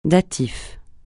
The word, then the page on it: datif: francais